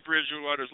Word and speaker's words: Bridgewater: So we've added two syllables to Teddy Bridgewater's last name. It's Teddy Bridgewater.